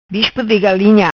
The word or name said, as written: Bispo Di Galinha